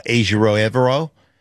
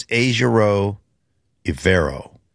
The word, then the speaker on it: Ejiro Evero: I think I said that right. I practice it all the time when I call the games, and then I forget instantly afterwards. – He was here in Denver, and we had, I think, four different pronunciations. – I know it's Ejiro Evero. Ejiro Evero. Who knows? I call him E-Squared.